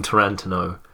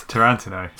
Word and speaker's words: Tarantino: So if I ever call him Tarantino, just understand why I've done that. It's not because I think that's how you pronounce his name. It's because the joke is on me. – Okay. – I messed it up. So anyway, we're talking about Quentin Tarantino. – It's Tarantino.